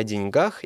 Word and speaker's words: деньгах: Деньги всегда кончаются, поэтому ставьте ударение на окончание. Не ошибетесь. Так что правильно говорить о деньгах и делиться деньгами.